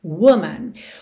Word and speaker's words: woman: Woman é W-O-M-A-N. Women é W-O-M-E-N. Ou seja, oficialmente, na escrita, o que muda é a segunda sílaba, mas na pronúncia é a primeira sílaba que muda.